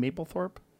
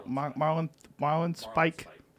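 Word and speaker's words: Marlinspike: How do you say his last name? Mapplethorpe? No. Marlon Spike. Marlon Spike.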